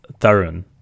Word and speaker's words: Tharun: but it's like, you know, if you were saying it like my mom says it, it's Tharun.